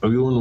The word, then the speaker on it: Eoghan: I think it's Owen Walls. It's spelled E-O-G-H-A-N.